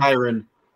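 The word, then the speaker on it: Kyren: Kyrie Williams, is that his name? – Kyron. – There you go.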